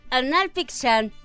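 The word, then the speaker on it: Hanal Pixán: anal Oye, che, pero anal lleva H y no se pronuncia, entonces más bien es. No, pero no lo voy a pronunciar. – Anal pixan.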